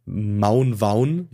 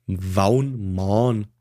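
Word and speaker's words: Waun Mawn: Im Gegensatz zu Stonehenge hat äh, dass, dass äh, der Megalithkreis in, in Wales, also Maun Waun, ich nenne es eigentlich Maun Waun, es wird eigentlich Waun Maun ausgesprochen, aber ist mir einfach zu schwierig.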